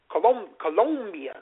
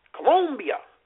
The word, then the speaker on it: Colombia: But anyway, it came down to the woman from the Philippines and the woman from Colombia. Colombia. You got to say it with the long O. Colombia.